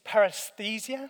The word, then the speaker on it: paresthesia: It's paresthesia. Is that right, Mike? Is that? Oh, is it? Okay. There you go. I knew I was going to mess up trying to look clever. Paresthesia.